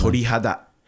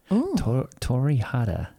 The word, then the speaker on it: torihada: Torihada. – Torihada.